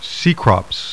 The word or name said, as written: CECROPS